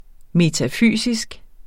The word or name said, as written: metafysisk